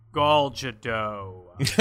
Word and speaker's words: Gal Gadot: Godot. I think it's Gal Gadot. – I think so too. I think you're right. According to the 9-second video on YouTube, Gal Gadot.